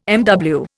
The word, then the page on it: MW: Indian